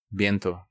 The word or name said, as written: Viento